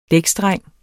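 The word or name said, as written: DÆKSDRENG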